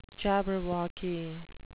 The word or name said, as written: jabberwocky